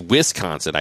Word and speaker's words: Wisconsin: I'm not even going to say it right because he says, great podcast, but it's Wis, not Wes, when you say Wisconsin. So it's Wisconsin, I guess.